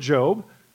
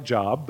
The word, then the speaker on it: Job: The book of Job, it seems like it's spelled like Job, the book of Job, right?